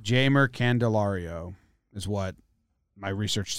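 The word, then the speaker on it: Jeimer: I've been saying Jamer. – It is Jamer. – It is Jamer. I like Heimer. – It's not Heimer. It's Jamer. I heard the Tigers announcers say that, and I double-checked it.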